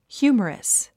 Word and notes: humorous: hjúːmərəs; ヒューマラス

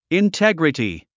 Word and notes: integrity: intéɡrəti; インテグリティ

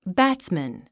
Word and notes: batsman: bǽtsmən; バッツメン

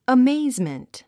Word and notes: amazement: əméizmənt; アメイズメント

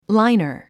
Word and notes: liner: láinər; ラァイナァ

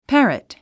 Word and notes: parrot: pǽrət; パァラット

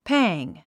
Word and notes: pang: pǽŋ; パング

